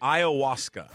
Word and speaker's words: ayahuasca: How do you correctly pronounce it? You got it yet? – Ayahuasca. – Okay. And it's this is like 15 different people saying you're a moron. It's pronounced ayahuasca.